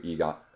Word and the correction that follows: Aegon: How do you say that name? Sorry, I'm butchering. – Aegon. – Aegon.